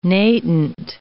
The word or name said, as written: natant